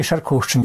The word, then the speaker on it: Шаркоўшчына: Як правильно говорить, Шаркоўшчына ці Шаркоўшчына? – Як я родился тут, в этом районе, и от народжения все называли только Шаркоўшчына.